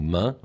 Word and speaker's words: Meung: Oui, Mac du Noir, ça veut dire donc originaire d'un petit village qui s'appelle Meung-sur-Loire, qu'on prononce nous Main, mais que quand les gens ne connaissent pas, on prononce Meung.